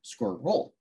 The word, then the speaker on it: squirrel: Some suggest that squirrel could be included, but squirrel is intended to be pronounced as a two-syllable word, squirrel.